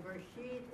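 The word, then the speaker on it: Bereshit (בְּרֵאשִׁית): Because sometimes, all the time anyway, when the shaba is in the very beginning word, let's say brashit. Brashit. Okay? Here. Am I doing? Oh, no. Bereshit is. Bereshit. There you go. Bereshit. Okay, this one, you pronounce the quick eh or the short eh. Bereshit.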